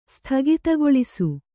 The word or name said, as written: ಸ್ಥಗಿತಗೊಳಿಸು